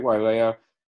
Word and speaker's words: Wailea: I think I pronounced it right. Wailea.